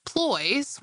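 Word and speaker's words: ployes: Not quite crepes, ploys, which rhymes with boys, are similarly thin, griddled goodies that cook on just one side.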